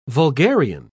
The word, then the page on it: vulgarian: British English